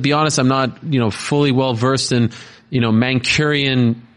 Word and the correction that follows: Mancunian: celebrity scene. Did I say that correctly? – Mancunian. – Mancurian. No, is that wrong? – Mancunian. – Cunian. Mancunian. – There we go. – My bad. Mancurian sounds like a manicure.